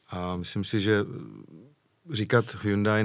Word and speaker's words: Hyundai: Takže pokud mě se zeptáte, jak já to říkám, tak já říkám Hyundai a je to fajn,